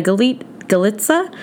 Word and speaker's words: Galica: Lombardi is not the only woman in this race with Davina Galitsa. Her name is spelled G-A-L-I-C-A, but I know that it's Galitsa, it's not Galica, who was a former Olympic skier, also racing.